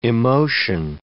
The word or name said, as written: emotion